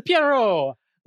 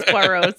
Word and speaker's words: Poirot: I love this for us because it means our poll is Poirot, Lisbeth Salander. – Sorry, I just can't let the way you pronounce Poirot stand. – Poirot. – Hercule. – Poirot. There we go. That's the best way. Okay.